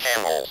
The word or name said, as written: camels